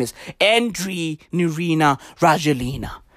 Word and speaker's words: Andry: A-N-D-R-Y. His surname is Rajoelina. R-A-J-O-E-L-I-N-A. Andre Rajoelina. Not Andy. Not Andrew. No. Andre. Dre. Or Andre. Rajoelina.